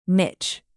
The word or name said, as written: mitch